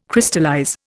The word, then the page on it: Crystallize: Indian